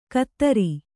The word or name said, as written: ಖಾತ್ರಿ